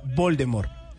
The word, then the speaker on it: Voldemort: porque según ella no se pronuncia la T del final, sino que se pronuncia Voldemort no, Voldemort. Y todos estábamos diciendo Voldemort, porque estaba la puesta la T ahí, de Voldemort.